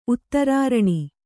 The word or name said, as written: ಉತ್ತರಾರಣಿ